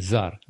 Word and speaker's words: czar: Или «зар» читается.